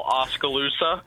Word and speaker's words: Oskaloosa: Gosh, great job pronouncing the name, first of all, Oskaloosa.